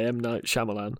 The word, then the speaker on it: M. Night Shyamalan: I'm not laughing at that because it'll remind me of the trauma of not being able to say M. Night Shyamalan.